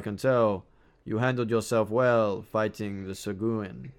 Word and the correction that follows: Sahuagin: I'm going to mispronounce this. Sahuagin. It is.